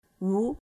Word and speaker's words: Wu: For many times, when I talk to people over the phone, if they asked for my surname, at first I will say Wu. Then I starting to receive letters with the name of Teresa Wood, as in Wood.